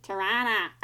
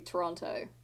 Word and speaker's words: Toronto: No, I think, how do you say Toronto? – Well, I'm gonna say it wrong. – Back home, my dad says Tirana. – Tirana? I would say Toronto, but I know that you don't pronounce the second T. – Yeah, but I do sometimes. I don't know, I switch it up.